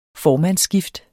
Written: formandsskifte

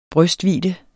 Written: brystvidde

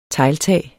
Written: tegltag

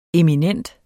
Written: eminent

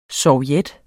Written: SOVJETTERNES

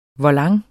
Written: volant

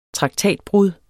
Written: traktatbrud

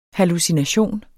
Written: hallucination

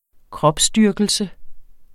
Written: kropsdyrkelse

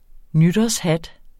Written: nytårshat

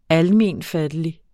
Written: almenfattelig

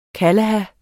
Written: kalaha

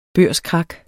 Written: børskrak

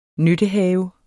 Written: nyttehave

